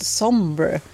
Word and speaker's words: somber: Ja, men det verkar som det är uttal. Som engelsk ordet somber.